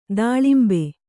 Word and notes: ತಿಲಾಂಬು: talāmbu

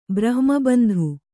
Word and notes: ಬ್ರಹ್ಮಬಂಧು: brahma bandhu

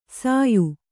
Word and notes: ಸಾಯು: sāyu

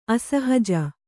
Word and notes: ಅಸಹಜ: asahaja

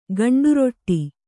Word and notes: ಗಂಡುರೊಟ್ಟಿ: gaṇḍu roṭṭi

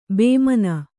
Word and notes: ಭಾಮಿನಿ: Kannada

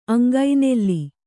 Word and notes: ಅಂಗನೂಲು: aŋganūlu; dial.

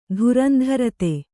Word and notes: ಧುರಂಧರತೆ: dhurandharate

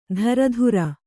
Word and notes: ದರದುರ: daradura; Kannada

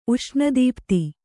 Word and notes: ಉಷ್ಣದೀಪ್ತಿ: Kannada

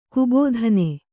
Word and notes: ಗಬ್ಬಿತನ: gabbitana; Kannada

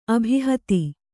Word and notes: ಅಭಿಹತಿ: abhihati

